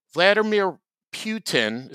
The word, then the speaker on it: Vladimir Putin: Vladimir Putin, is that how you say his name? – Yes.